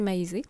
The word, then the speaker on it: Maize: La première fois que Maisé Longboat, bon, son prénom, ça, s'écrit M-A-I-Z-E, alors je vais le prononcer Maisé,